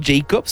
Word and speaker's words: Jakwob: Podrías pronunciarlo Jacob, supongo, pero se escribe J-A-K-W o B grande. Entonces, Jacob, I guess.